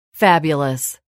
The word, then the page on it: fabulous: [fǽbjuləs]